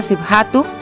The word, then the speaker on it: Sibhatu: Bueno, pues ahí estuvo Nuestros Ecos Extranjeros desde Eritrea con esta autora Rika siphatu. Sibhatu. Búsquela, Ripka Sibhatu se escribe R-I-B-K-A. Sidhatu es S-I-D-H-A-T-U.